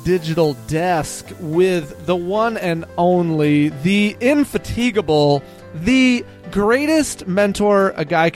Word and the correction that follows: indefatigable: If you're going to give me a great word like that, you got to pronounce it right. The indefatigable.